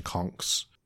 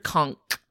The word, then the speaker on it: conch: Earlier I mentioned conchs. There are two ways to pronounce that. – I've always heard conch with K sound. – That's a Florida thing, I think.